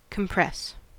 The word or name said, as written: compress